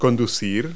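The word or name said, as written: steer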